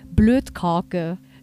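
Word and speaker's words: bløtkake: So böse ist auch das norwegische Wort für Sahnetorte, Blödkarke. Schreibt man zwar nicht mit D, sondern mit T und mit diesem skandinavischen Ö.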